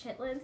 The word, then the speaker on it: chitterlings: It's spelled chitterlings, but it's chitlins.